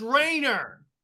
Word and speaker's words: Rayner: It's pronounced Rayner. – Rayner, yeah.